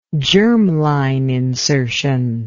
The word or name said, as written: germline insertion